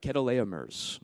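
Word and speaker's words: Kedorlaomer's: Then he pursued Kedoleomer's, I got that right, Kedoleomer's is how it's pronounced, army until he caught up with them at Dan.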